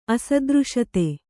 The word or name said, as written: ಅಸದೃಶತೆ